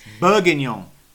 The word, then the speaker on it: bourguignon: Bourguignon. – Thank you. – She always puts a soft G in.